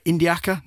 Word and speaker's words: Indiaca: So we're, am I saying it right? Indiaca, that's right? – Yeah, that's right.